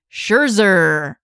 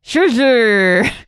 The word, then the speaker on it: Scherzer: So the top four pitchers on my poll were Clayton Kershaw, Corey Kluber, Chris Sale, and Max Scherzer. Hey, I pronounced it right the first time. For whatever reason, that's difficult for my Northeastern Ohio dialect to do. I'm usually sounding like I'm brushing my teeth.